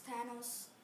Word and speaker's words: Thanos: What is Thanos pronounced? Thanos.